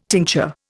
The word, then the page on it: tincture: Indian